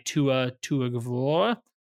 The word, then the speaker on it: Tua Tagovailoa: He finally got some involvement last week with his new guy, Tua Tagovailoa. – There you go. You got the name. That was nailed exactly it. How you pronounce it.